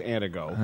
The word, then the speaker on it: Antigo: And a 608 texter says, Dan said Antigo right the first time. He says, as a Wisconsinite, it is Antigo.